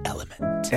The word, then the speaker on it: LMNT: It's the letters L-M-N-T, and it's pronounced Element.